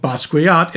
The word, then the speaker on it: Basquiat: Jeffrey Wright, who plays Dr. Watson in this movie, played the title character in Jarmusch's film Basquiat in 1996. – It's just Basquiat. You don't pronounce the W, the U.